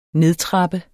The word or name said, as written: nedtrappe